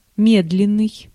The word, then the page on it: медленный: /ˈmʲɛdlʲɪnɨj/